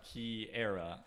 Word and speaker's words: Kiara: Say it correctly episode. With me. – Kiara. – Kiara. Spelled K-I-A-R-A.